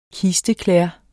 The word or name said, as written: kisteklæder